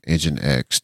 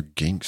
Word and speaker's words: NGINX: NGINX. Yeah, that is how you say it. I'm sorry. I hear gink so much. I was like, wait, that's not how you say that. I should really go fix that pronunciation because, man, I really got stuck.